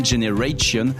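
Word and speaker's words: Gener8ion: Ça s'écrit Gener 8, I-O-N, Generation.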